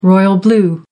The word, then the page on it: royal blue: American English